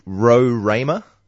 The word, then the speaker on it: Roraima: AB Bishop has just messaged me saying that Roy, it's pronounced Roraima. Yes.